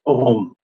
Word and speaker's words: OHM: So OHM as a whole could also be pronounced as OM, which, as some of you may know of Indian mythology, the word has a very deep meaning.